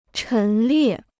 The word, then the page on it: 陈列: chén liè